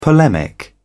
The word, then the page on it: polemic: British English